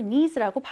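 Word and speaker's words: knees: 자, 여기서 knees도 Climb의 B처럼 K가 무음이어서 발음하지 않습니다. 따라서 bend your knees라고 발음해 주시면 돼요.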